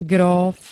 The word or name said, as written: GRÓF